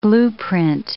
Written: blueprint

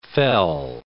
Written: fell

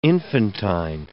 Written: infantine

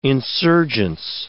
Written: insurgence

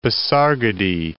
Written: Pasargadae

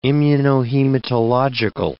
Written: immunohematological

